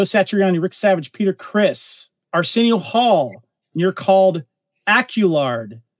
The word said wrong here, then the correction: Alucard: No, am I saying that right? – Alucard. – Alucard, sorry. Alucard. Dracula backwards.